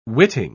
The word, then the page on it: witting: British English